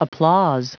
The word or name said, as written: applause